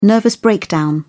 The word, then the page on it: nervous breakdown: British English